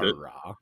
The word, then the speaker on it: Aarau: I don't know if that's the correct pronunciation. A-A-R-A-U. – We'll go with Aarau.